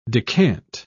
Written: decant

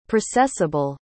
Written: processable

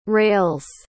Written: rales

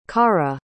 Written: karah

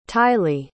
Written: tylie

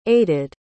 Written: aided

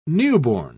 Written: newborn

